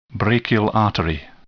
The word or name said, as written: brachial artery